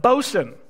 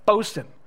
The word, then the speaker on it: boatswain: The meteorologist, the naturalist, the carpenter, the machinist, the bosun, not boatswain as it is spelled, but bosun. I checked multiple online dictionaries to make sure that I said that right. Bosun.